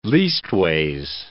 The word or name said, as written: leastways